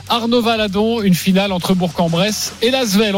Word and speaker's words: Bourg-en-Bresse: seulement pour Bourg, parce qu'on prononce bien là, la fin euh, pour Bourg-en-Bresse.